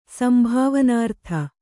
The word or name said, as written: ಸಂಭಾವನಾರ್ಥ